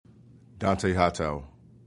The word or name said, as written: Hightower